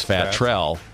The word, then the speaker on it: Fat Trell: It is two words, Fat Trell. But you can pronounce it as though it's one word.